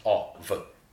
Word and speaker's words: of: We read that F as a V sound. – Absolutely. OF. – And you hear that vowel, that OF, is not an O at all. – Yeah, it's like a short O. Loveliest OV.